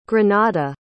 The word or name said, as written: grenada